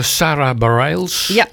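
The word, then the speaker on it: Sara Bareilles: En dat doet Sarah Bareilles, als ik het goed uitspreek. – Klopt.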